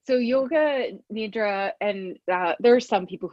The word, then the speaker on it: nidra: But so it's traditionally pronounced yoga nidra. And that means, so yoga means union and nidra means sleep.